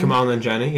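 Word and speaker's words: Kumail Nanjiani: It's got a stacked cast with Kamal Nanjiani. Is that how I say his name? – Kamal Nanjiani, yeah.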